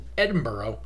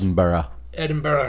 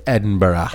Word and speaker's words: Edinburgh: Like Pure Li-Fi is a technology company based in Edinburgh, and they introduced the world's Edinburgh. It's pronounced Edinburgh.